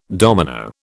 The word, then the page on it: domino: British English